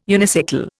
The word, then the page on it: unicycle: Indian